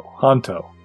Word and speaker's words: Hanto: How is it pronounced? We've never canonically said. – Hanto. I feel like it's been pretty established.